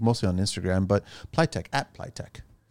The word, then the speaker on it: Pliteq: It's spelled P-L-I-T-E-Q. – Pliable technology is where that comes from. – That's where it comes from? – Yeah.